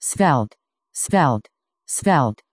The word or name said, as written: svelte